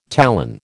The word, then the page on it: talon: American English